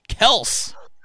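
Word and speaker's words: Kelce: it's actually not Kelsey. It's Kels. – Wow. – It's pronounced Kels.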